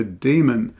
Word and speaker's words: daemon: We're introduced to Lyra Balacqua, a young girl who's accompanied by her demon, Pantalaimon, a small furry animal. What is a demon? This is spelled D-A-E, by the way, or a diphthong, daemon, demon.